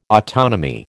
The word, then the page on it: autonomy: American English